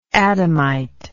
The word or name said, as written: Adamitical